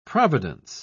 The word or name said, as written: providence